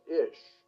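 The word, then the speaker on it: Ish (אִישׁ): Man in Hebrew is pronounced Ish and looks like this.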